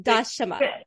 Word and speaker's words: Dashama: Just wanted to, my name is pronounced Dashama, but some people call me Dasha if it's hard to remember the pronunciation. – So yeah, one more time, Dashama.